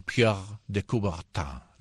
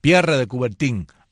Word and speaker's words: Pierre de Coubertin: Y fue creado un día como hoy, 24 de junio de 1894, por el barón Pierre de Coubertin. ¿Lo pronuncié bien, Exilda? – Sí. – Pierre de Coubertin.